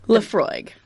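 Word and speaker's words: Laphroaig: It's a Lafroig.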